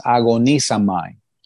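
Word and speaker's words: agonisomai (ἀγωνίζομαι): La palabra original en griego, la primera que dice Jesús, es agonisomai. – You recognize the word? – ¿Reconocen la palabra? – It's really easy if you see how it's spelled. It's like agonize.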